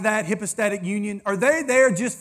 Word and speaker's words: hypostatic: To give you a word to impress all your friends, that's called hypostatic union. It's spelled like hippo, but it's hippa.